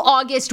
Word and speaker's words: August: I always want to say it, Augusta, but it's August.